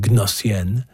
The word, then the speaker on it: Gnossienne: That's what the silent G, in case you're wondering, Gnossienne, number one, played by the Montreal pianist Bruce Liu.